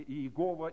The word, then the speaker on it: Яхве: Об имени Яхве, Яхова, Яхова. То есть вначале это имя было Яхве, потом его стали произносить Яхова или Иегова, или как мы говорим Иегова.